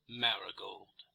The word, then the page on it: marigold: American English